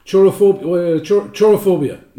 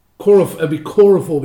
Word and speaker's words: Chorophobia: Chorophobia. No, that's not the right way to pronounce that. Chorophobia would be the correct way to pronounce that. Chorophobia. C-H-O-R-O phobia.